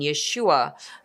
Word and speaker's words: Yeshua: Jesus in English, but here we also call his name in the original pronunciation, Yeshua.